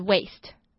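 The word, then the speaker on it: waist: 那这个字的发音呢，跟腰部的发音一模一样，可是拼法不太一样，是 w-a-i-s-t，可是也是 waste。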